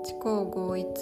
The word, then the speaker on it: 知行合一: 漢字は知るに行く合わせる一と書いて知項合一と読むそうです